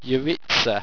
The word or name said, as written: yeritza